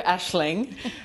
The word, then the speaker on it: Aisling: The first name is perfect, though, Aisling. she gets called some weird names like Aisling and Aisling. – For my listeners, it's spelled A-I-S-L-I-N-G. – Okay. – So it's an Irish name. – Yeah. – And the Gaelic names never sound how they look.